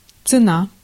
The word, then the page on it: цена: /t͡sɨˈna/; tsena